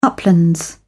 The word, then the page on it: uplands: British English